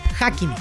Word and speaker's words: Hakimi: Bono, Yakimi, Diop. – Hakimi. – ¿Eh? – Hakimi. – ¿Dónde es que yo dije Hakimi? – No, Hakimi. Se escribe con H.